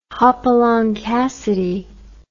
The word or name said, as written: Hopalong Cassidy